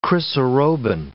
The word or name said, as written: chrysarobin